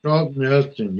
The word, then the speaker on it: Charlton Heston: Charlton Heston, yes.